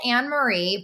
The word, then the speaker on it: Annemarie: It's spelled Annemarie, but she says it Annemarie because she's Dutch.